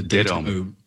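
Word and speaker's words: dataome: You conceive a new word, as far as I know, in the book, and I think I'm going to say this right. Is it data-ome? – Yes, that's right. The data-ome.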